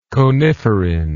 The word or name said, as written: coniferin